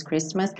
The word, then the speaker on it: Christmas: Só lembrando essa pronúncia do Christmas, que embora Cristo, ou seja, se você tirar o M e a S, você vai pronunciar Christ, mas o Natal é Christmas, tá bom? Não é Christmas.